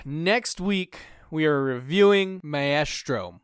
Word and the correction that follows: Maestro: You butchered that so bad. Maestro. – Yeah, Maestro. – You overthought it. – I really pronunciated the A-E-S there, really.